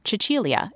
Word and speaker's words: Cecilia: At one of the gatherings at the casino, he met a dark-haired beauty named Cecilia, or Cecilia, if we're going by the authentic Italian pronunciation.